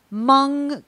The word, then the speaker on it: Hmong: You say Hmong cuisine, correct? – Yeah. – Okay, good. – So, yep. So, you know, we make Hmong food. You know, I think the best way for me to explain Hmong food is to say that Hmong food isn't a type of food, but it's a philosophy of food.